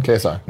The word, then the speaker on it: KSI: How much did Logan Paul and KSI make, is it KSI, is that how you say his name? – KSI.